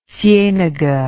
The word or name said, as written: cienaga